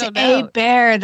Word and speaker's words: Hebert: Now you got me wondering what Hebert means in French. – Oh, a bear. It's a bear. That's how you pronounce it.